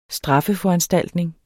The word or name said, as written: straffeforanstaltning